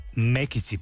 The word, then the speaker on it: мекици: Другата, чудесни мекици, както ги наричаше мекици, пардон, да, ето даже ударението виждам, да, така е, наистина тези вкусове се връщат с пълна сила и тези уникални неща, които нашите баби са правили.